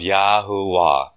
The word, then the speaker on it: Yahuwah: I'm going to read it with the correct phonetically Hebrew pronounced name where it says the Lord in your authorized King James Version. Therefore hear ye the word of Yahuwah.